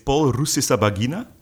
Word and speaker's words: Paul Rusesabagina: Wel, hij heet Paul Rusesabagina.